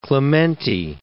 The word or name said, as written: Clementi